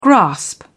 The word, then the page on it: grasp: British English